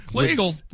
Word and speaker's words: Kleagle: Kleagle rhymes with beagle.